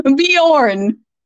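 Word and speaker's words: Björn: Bjorn.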